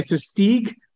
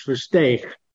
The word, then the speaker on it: Versteeg: Yeah, I never get Versteeg, as if you're clearing out your throat. Most Americans say Versteeg, and the Dutch pronunciation, my original heritage, Versteeg. So Versteeg is fine. Versteeg, if you're clearing your throat, not always an easy one.